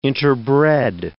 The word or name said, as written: interbred